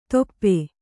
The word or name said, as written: ದೊಪ್ಪೆ